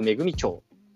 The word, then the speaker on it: 関屋恵町: オリジナル読み方、関谷めぐみ町。新潟県新潟市中央区、関谷めぐみ町、あ、恵み町。